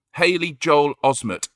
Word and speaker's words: Haley Joel Osment: It's Haley Joel Osment.